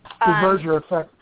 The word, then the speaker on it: Duverger effect: the Duverger effect? – Duverger effect, yeah.